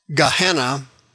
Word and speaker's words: Gehenna: Gehenna is pronounced in English Gehenna.